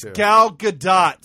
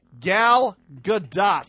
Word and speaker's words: Gal Gadot: But we could talk about that Gal Gadot salary thing next week, It's Gal Gadot, sir. – Oh, sorry. – And I am sick of people who are calling me out and texting me in every other way saying, you're saying it wrong, you sound like an idiot.